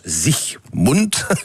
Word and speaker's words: Siegmund: Alors, l'homme fort qui a permis cette victoire, c'est Ulrich Siegmund, en allemand ça se prononce Siegmund. Pas d'algage, je n'ai pas dit Sieg Heil, j'ai dit Siegmund, ça n'a strictement rien à voir.